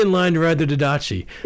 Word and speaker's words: Didache: What did you call that ancient document, the Didache? Is that how you pronounced it? – Yeah, that's how I pronounce it. Other people could say the Didache, or, yeah, you know, yeah.